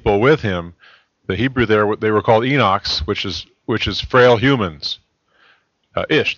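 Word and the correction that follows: Enosh (אנוש): Or however Enosh, you would pronounce Enosh. Okay, Enosh. Thank you.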